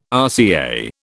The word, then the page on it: RCA: British English